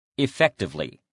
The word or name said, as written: effectively